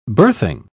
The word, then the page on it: birthing: British English